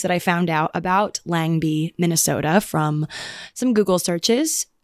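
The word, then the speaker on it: Lengby: It's spelled Langby, L-E-N, but it's pronounced Langby in Minnesota.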